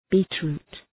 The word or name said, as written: beetroot